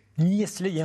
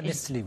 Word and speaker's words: Niestlé: C'est ça le Delachaux et Niestlé, hein. Nestlé, oui. – Voilà, je préférais que vous le prononciez vous-même.